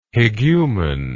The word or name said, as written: hegumen